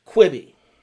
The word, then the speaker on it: Quibi: So unless you're really media savvy, or even if you are really media savvy, you may be wondering to yourself, what is Quibi? You may not even know that that's how it's pronounced, because that's how badly they botched this.